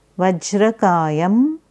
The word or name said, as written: Vajrakayam